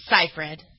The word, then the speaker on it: Seyfried: Is it Seyfried? Is that how you pronounce it? – Seyfried. – Seyfried.